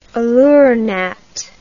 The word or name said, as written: aleuronat